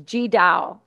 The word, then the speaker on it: Tri Dao: It's G Dao. It's, but it's spelled T-R-I-D-A-O.